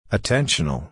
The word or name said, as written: attentional